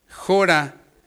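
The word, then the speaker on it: jora (χώρα): Aquí la palabra cabida del griego, jora, con acento en la O, dice extensión de espacio, de territorio.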